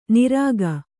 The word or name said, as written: ನಿರಖ್